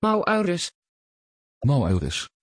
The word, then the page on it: Maurus: Dutch